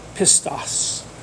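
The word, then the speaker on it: pistos (πιστός): It's the Greek word pistos is how it's spelled, P-I-S-T-O-S, pistos.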